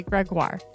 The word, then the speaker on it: Gregoire: Plus, today's guest, Sheila Wray Gregoire, pronounced like Jaguar.